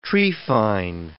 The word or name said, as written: trephine